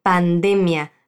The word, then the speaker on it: pandemia: Pandemia se dice así de manera distinta del portugués, ¿lo has pillado? La sílaba tónica de esta palabra en español es la de pandemia. Repite, pandemia. Así es.